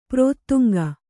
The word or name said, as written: ಪ್ರೋತ್ತುಂಗ